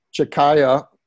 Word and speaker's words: Tchiccaya: we were thinking about Chikaya, only the T is silent because her name is spelled T-C-H-I-C-C-A-Y-A with the T being silent.